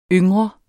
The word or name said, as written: yngre